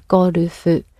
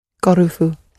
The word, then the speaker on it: ゴルフ: So with golf, you're breaking it down into a consonant vowel sound, ゴルフ, and each of those sounds, apart from the go, the other two are followed by an u sound, ゴルフ, ゴルフ.